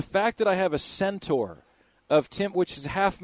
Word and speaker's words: centaur: You don't separate the syllables. Okay. Centaur.